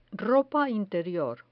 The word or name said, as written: ropa interior